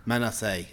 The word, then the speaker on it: Menace: How did you pronounce it? The Phantom... – Menace. – Okay. Yes. I'm not going to say it like that again.